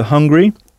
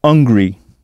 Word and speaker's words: hungry: Avez-vous bien prononcé le H aspiré de hungry? C'est le même que celui de hot. Beaucoup de Français font en effet l'erreur de ne pas prononcer le H de hungry et du coup on entend hungry, qui peut être confondu avec angry, A-N-G-R-Y, qui veut dire fâché, en colère.